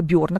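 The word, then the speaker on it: Berne: Да, потому что благодаря транзактному анализу Эрика Бёрна, кто-то его называет Берн, я называю Бёрн, потому что вообще на всех языках он читается как Бёрн, как недаром ему Нобелевскую премию дали за это.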